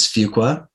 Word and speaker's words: Fuqua: It is Fuqua, and they taught us that.